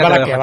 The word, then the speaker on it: Valaquia: No, es Valaquia, Valaquia. Se puede escribir Valachia, como también se escribe en algunos sitios.